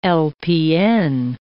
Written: LPN

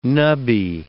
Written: nubby

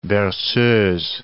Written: berceuse

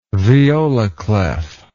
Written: viola clef

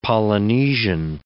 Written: Polynesian

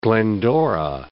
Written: Glendora

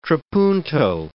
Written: trapunto

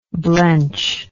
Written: blenchingly

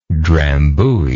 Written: drambuie

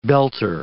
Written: belter

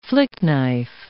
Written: flick-knife

